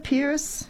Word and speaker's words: Pieris: Yeah, so Gippsland, Waratah, and leaves from Pieris. I'm not sure how you pronounce that. You would. – Yeah, Pierce. Yeah.